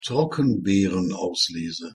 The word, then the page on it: trockenbeerenauslese: German